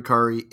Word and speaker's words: Kari: And again, we'll put the pronunciations, the correct pronunciation is Kari, so kindly put in the descriptions of these of this episode.